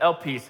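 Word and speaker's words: elpis (ἐλπίς): This word, elpis, all right? That's how you pronounce it, elpis.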